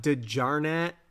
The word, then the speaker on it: DiGiorno: I assume the J DiGiorno. – Is not, yeah, DiGiorno. It's not delivery. It's DiGiorno. I'm assuming it's not DiGiorno. I'm assuming it's not a hard J. I'm assuming it's DiGiorno.